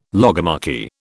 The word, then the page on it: logomachy: British English